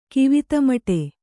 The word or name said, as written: ಕಿವಿತಮಟೆ